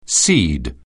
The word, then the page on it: cede: anglais